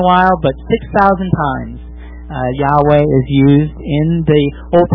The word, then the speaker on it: Yahweh: However, I would submit that Yahweh is actually the better pronunciation because it's the Hebrew pronunciation.